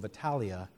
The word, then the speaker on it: Italia: For though Virgil could at his own pleasure make the first syllable of Italia long, while the ancients pronounced it short,